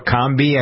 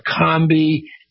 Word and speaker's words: Orkambi: Well, apparently there's a new drug called ORKAMBI, or Kambi, I guess is how it's pronounced.